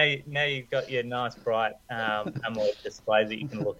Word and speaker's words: AMOLED: Yeah, besides Croker's bad pronunciation of Amoled, not Amold, but it was a great list.